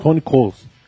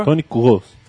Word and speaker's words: Toni Kroos: Toni Kroos. – Ele pegou e... – Como a pronúncia, por favor? – Toni Kroos.